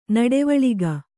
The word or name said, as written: ನುಡಿವಳಿಕೆ